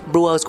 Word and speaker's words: Brewers: Hop Coffee Brewers Brewers của em có S không nhỉ? – Dạ có. – Thế thì anh đọc đúng anh anh phát âm đúng.